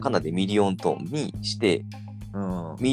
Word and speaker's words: 百万音色: で、 100 万音色ってして、ミリオントーンって読むやろ。